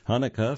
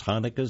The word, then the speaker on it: Hanukkah: Hanukkah, frequently spelled with a silent C as the first letter, is an eight-day commemoration of the miracle of light that ended December 6th this year.